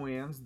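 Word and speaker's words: WAN: LANs and WANs, that's how we pronounce it, not lanes and wanes, but LANs and WANs, local area networks, wide area networks.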